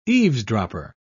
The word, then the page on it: eavesdropper: British English